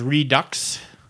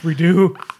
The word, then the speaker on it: Redux: My recommendation is a board game called Romance of the Three Kingdoms Redux. Although I always pronounced R-E-D-U-X redo, but all the reviews and videos I watched, they said redux. So maybe I think that's wrong. It's redo.